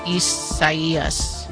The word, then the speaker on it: Isaias: Well, no, but it's probably the Spanish rendering of it, which if that's the case, then the I is pronounced like an E. – Isaias.